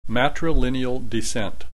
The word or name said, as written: matrilineal descent